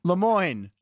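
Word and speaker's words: Le Moyne: LeMoyne. Rhymes with Des Moines.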